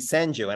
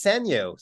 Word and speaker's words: Sanyo: but I had a few guys who were saying Sanjo, and I was like, that's not right. His name is Sanyo.